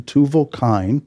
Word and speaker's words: Tuval-Cain: Lamech's second wife gives him another son and a daughter, and the occupation of this third son, Tuval-Cain, is the correct pronunciation, Tuval-Cain, just may play a role in the poem or song that closes out this episode.